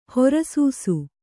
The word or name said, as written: ಹೊರಸೂಸು